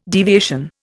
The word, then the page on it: deviation: Indian